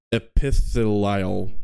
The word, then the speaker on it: epithelial: Epithelial cells.